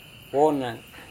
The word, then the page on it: wonang: Serewen